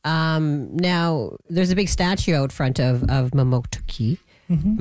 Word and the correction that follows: Mamotokuti: Am I saying that right? Mamotoki? – No, Mamotokuti. – Mamotokuti.